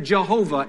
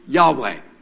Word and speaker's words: Yahweh: In the Old Testament, this capital L, small caps, O-R-D, refers to God's proper and personal name. Theologians of centuries before called it Jehovah in English. Today, they're telling us the right way to pronounce it is Yahweh.